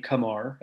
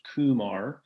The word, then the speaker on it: Kamar: We learned his preferred pronunciation of his first name, Kamar, as opposed to Kumar.